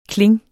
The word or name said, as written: kling